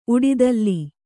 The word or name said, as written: ಉಡೆದೋಲ್